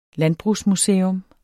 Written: landbrugsmuseum

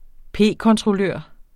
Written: p-kontrollør

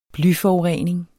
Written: blyforurening

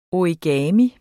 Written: origami

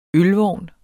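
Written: ØLVOGNE

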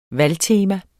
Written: valgtema

